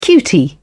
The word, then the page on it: cutie: British English